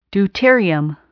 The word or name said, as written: deuterium